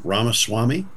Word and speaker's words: Ramaswamy: And Ram, did we ever figure out how to pronounce Vivek's name? Ramaswamy. – Yeah, Is that right? – I thought I did a couple times.